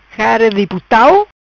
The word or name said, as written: Cara Di Putau